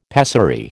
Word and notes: pessary: American English

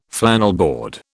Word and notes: flannelboard: British English

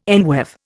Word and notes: enweave: Indian

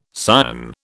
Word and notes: Sion: British English

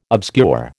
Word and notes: obscure: American English

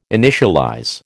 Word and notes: initialize: American English

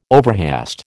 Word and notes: overhaste: American English

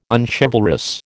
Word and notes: unchivalrous: American English

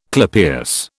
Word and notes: clypeus: British English

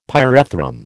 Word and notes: pyrethrum: American English

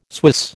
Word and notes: Swiss: American English

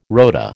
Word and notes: rota: American English

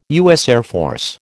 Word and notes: USAF: American English

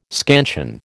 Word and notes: scansion: American English

